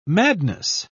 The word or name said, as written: madness